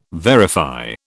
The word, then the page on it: verify: British English